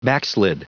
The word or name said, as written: backslid